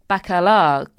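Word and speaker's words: Bacalar: But what we've ended up with is the Bacala group. Pronunciation, verification, Matt? – Sounds good.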